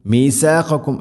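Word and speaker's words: മീഥാഖക്കും (مِيثَاقَكُمْ): മീഥാഖക്കും, മീഥാഖക്കും എന്നല്ല, മീഥാ മീ സീനാവരുത്. അതുപോലെ ഖാഫും കാഫും മഖ്റജ് അടുത്തുള്ള അക്ഷരമാണ്. മീഥാഖക്കും, ഖാക്കും, മീഥാഖക്കും. ലാ തസ്ഫികൂന ദിമാഅക്കും, ദിമാഅക്കും മദ് മുത്തസിലാണ്, നിർബന്ധമായിട്ടും നാലോ അഞ്ചോ ഹർക്കത്തിൻ്റെ ഖദറുകൾ നീട്ടണം.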